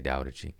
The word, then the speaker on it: Tao Te Ching: No, it's a D sound. – It's a D, okay, Tao Te Ching.